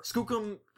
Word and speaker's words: Skookum: So Skookum's the proper. – Skookum, Proper way to say it.